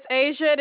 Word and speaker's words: Asia: Name is Asia. It is spelled A-S-I-A. – A-S-I-N.